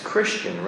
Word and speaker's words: Christian: The I there becomes a short I now. It's Christian, right?